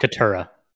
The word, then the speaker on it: Katara: I don't know if I'm even saying her name right, and I feel bad about it. – Katara. – Yeah. Katara. Mm-hmm. Katara. I'm so sorry. I've been saying it wrong this whole time, I think. – You're really close.